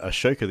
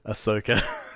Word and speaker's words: Ahsoka: I have been watching Ahsoka because I called it Ashoka the other day, and another Star Wars person gave me a bit of a grilling. So Ahsoka, I can't not pronounce the sh in the name.